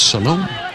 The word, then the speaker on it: Salome: Brazilian Isabella Tavares Salome. Looks like Solomon, but if you go to the website and you click on the ear, each player pronounces the proper pronunciation of their name. It's Salome.